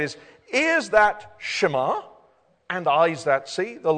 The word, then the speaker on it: shema: In the Hebrew language in which this was both spoken and written 3,500 years ago, the word for hear or listen is a Hebrew word pronounced in English, shema.